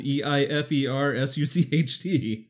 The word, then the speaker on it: Eifersucht: the song that is spelled E I F E R S U C H T.